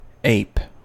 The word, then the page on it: ape: American English